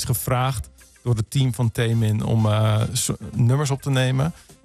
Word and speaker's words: Taemin: Nu heb je ook, en dat is ook wel bijzonder, heb je samengewerkt met een K-pop artiest, dat is Taemin, zeg ik dat goed? – Ja, dat zeg je goed.